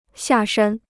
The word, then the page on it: 下山: xià shān